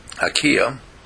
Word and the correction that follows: Akila: is that how you pronounce it? – Akila was a genius in the ability to work with words, and she found it, and then she did it.